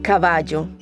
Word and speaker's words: caballo: Keep an ear out for our storyteller's Colombian accent, where the double L's and Y's are pronounced with a soft J sound. So words like caballo sound like caballo.